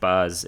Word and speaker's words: Bas: No, it's Baz, but it's pronounced Bas in like Germanic areas.